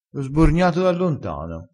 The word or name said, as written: sborgna